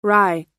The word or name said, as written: rai